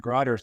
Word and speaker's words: Grutter: When I first heard it, I thought it would be spelled different than it is spelled. It sounds more like a D to me. The case is Grutter from 2003,